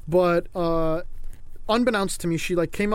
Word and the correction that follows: unbeknownst: You mispronounced it, that's all I'm saying. – Oh, did I actually? Fuck. – Unbeknownst. Like, known.